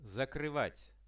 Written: zakryvat

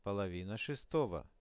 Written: polovina 6-ogo

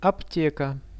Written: apteka

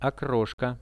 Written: okroshka

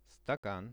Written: stakan

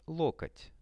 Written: lokot

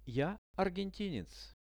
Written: ja argentinec

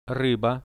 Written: ryba

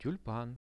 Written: tulpan

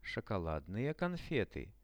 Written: shokoladnye konfety

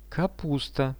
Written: kapusta

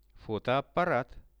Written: fotoapparat

